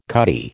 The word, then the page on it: cutty: American English